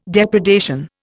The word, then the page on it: depredation: Indian